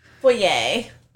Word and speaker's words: foyer: Yeah, she sets up front in the foyer. – And she says, pronounce foyer. – Foyer. – Look here. I am not French. – It's pronounced a foyer. Say it.